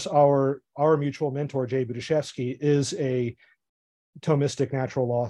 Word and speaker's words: Budziszewski: yeah Budziszewski